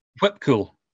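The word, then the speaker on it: Whipkül: And that's pronounced whip cool? Uh, whip cool, yeah.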